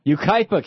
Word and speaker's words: Yucaipa: Yucca. How do you say that? Say it again. – Yucaipa, California. Yucaipa. Okay.